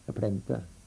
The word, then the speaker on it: plänter: Jaha, så skriver jag upp att ni har sagt hur ni har uttalat planter. – Ja, plänter.